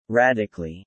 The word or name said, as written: radically